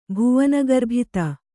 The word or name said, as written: ಭುವನಗರ್ಭಿತ